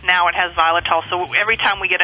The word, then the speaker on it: xylitol: No, it's strictly the xylitol, and it's, it's xylitol is spelled with an X, so it's X Y L I T O L, just so you know.